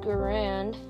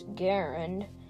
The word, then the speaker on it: Garand: Although the name Garand is frequently pronounced Garand, the preferred pronunciation is Garand, to rhyme with errand.